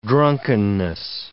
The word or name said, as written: drunkenness